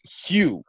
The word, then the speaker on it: Hugh: I go into Starbucks a lot and I say my name and they almost always write Q. For some reason, like I'm like Hugh, and then one day I was like, oh, I should just call myself Schoolboy Hugh on Instagram.